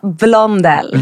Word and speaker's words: Blondell: Vi har fått Nanna Bloell. Hur uttalar man det? – Nej, säg en gång till. – Nanna Bloell. Nu är det namnhaveri igen. – Det är mycket enklare. Nanna Blondell.